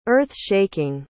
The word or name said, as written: earth-shaking